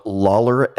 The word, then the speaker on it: Lawler: My last name is spelled Lawler, LAWLER.